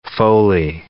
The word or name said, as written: Foley